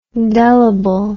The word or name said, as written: delible